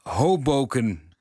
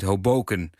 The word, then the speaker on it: Hoboken: Zie ik het als mijn plicht jullie mee te delen dat de klemtoon bij de Antwerpse deelgemeente Hoboken op de eerste lettergreep ligt. Dus niet Hoboken. Zoals bij de stad in New Jersey, waar, zegt Philip er nog even bij, Frank Sinatra werd geboren.